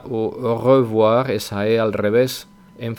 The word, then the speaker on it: au revoir: Ahí os he puesto que se pronuncia de dos maneras. Depende un poco de si estamos hablando rápido o si hablamos despacio y vocalizamos bien. En este último caso, que es el que vosotros deberíais empezar aprendiendo, es la primera pronunciación que pongo, que es au revoir, au revoir.